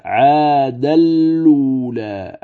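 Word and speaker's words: عادًا الأولى: الملاحظة الثانية قال المصنف الثانية كلمة عاد الأولى أو عادا الأولى، قال تنقل فيها حركة الهمزة إلى اللام الساكنة فتصبح اللام مضمومة، ثم تدغم اللام المضمومة في تنوين الدال قبلها إدغاما كاملا بغير غنة فتلفظ هكذا عاد الأولى عاد الأولى.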